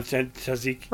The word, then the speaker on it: tzatziki: Tzatziki. Right. So that's how you say it.